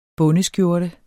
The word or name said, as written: bondeskjorte